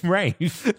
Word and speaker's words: Ralph: Rafe. – No one says Rafe.